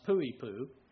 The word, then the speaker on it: Poipu: It was a little lower, but with the high humidity where we were staying at Pu'ipu, spelled P-O-I-P-U, but it's pronounced Pu'ipu, where we were staying there, the humidity made up for the lesser temperatures.